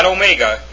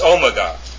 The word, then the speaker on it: omega: That's how you pronounce those letters, the Alpha and it's not omega, it's omega.